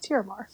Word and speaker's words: Tiramar: Tiramar? Or am I saying that right? – Yes, that is correct. Tiramar. – T-I-R-A-M-A-R? – Yeah.